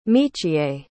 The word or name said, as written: michiye